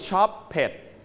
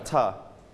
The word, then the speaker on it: shopped: So we don't pronounce it shop pet. No, นะครับนักเรียนปลายทางเช่นเดียวกันนะครับเวลาที่คำ regular verb เติม ed เราจะไม่อ่านออกเสียงเป็นอีกหนึ่งพยางค์ทุกคำนะครับทุกกรณีไม่ใช่นะครับในกรณีนี้ลงท้ายด้วยเสียง per. Everybody per. Per. Shop per. – Shop. – Shopped.